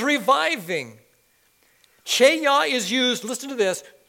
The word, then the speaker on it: chayah: There's a Hebrew word called cheyah. It's spelled C-H-A-Y-A-H. Say cheyah.